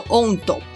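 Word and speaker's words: 音頭: 那鹰头呢，就是音乐的头，日文念作 ondo，它是日本传统曲调中的一个形式，一个人主唱，或是会有个主乐器演奏，然后后面再加入其他的乐器或是其他人来大合唱。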